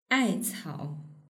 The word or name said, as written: aicao